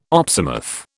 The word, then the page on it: opsimath: British English